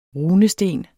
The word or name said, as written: runesten